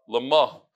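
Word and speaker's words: Lama (למה): Lama. The emphasis is on the mem.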